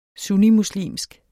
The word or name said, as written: sunnimuslimsk